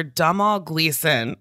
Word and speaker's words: Domhnall Gleeson: There's an actor, Domhnall Gleeson.